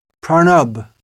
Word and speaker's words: Pranab: A recent president of the Republic of India was named Pranab, the Bengali pronunciation, Pranab Mukherjee.